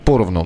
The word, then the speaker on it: поровну: на первый слог ударение, поровну, да, ну мне все поровну, Ясно, принято.